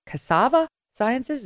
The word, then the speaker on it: Cassava Sciences: Cassava Sciences. Is that how you pronounce it? – Correct.